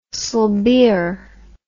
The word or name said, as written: sibir